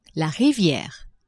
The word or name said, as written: riviere